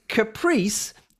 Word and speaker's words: Caprice: In American English, Caprice is pronounced exactly as Andy read it.